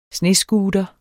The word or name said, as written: snescooter